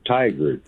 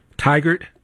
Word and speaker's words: Tigard: it almost sounds like tiger, but it's tiger. It's like T-I-G-A-R-D.